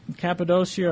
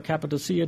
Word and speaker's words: Cappadocia: But this is reference to the northern part, where you'll see Pontus and Galatia goes down here, and there's the upper part, Cappadocia or Cappadocia, depending how you want to pronounce it.